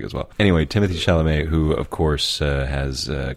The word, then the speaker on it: Chalamet: And Timothy, I think Chalamet is how he pronounces Chalamet? – It.